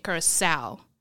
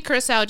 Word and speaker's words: Curacoa: It's spelled like Curacoa. – Okay. – But people have been pronouncing it Curacao. So at the end, it's O-A, which is not correct if you're going to say Curacao because it's A-O at the end.